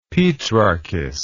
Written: petrarchist

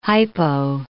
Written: hypo

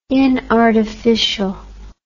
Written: inartificial

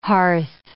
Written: hearth

